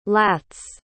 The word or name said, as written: lats